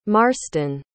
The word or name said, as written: marston